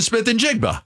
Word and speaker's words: Smith-Njigba: 50 is a little strong, but I would say the last 20 times you've used Jackson, Smith, and Jigba.